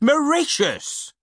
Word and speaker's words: Mauritius: It's pronounced Mauritius, said Mr. Sweaty Fox.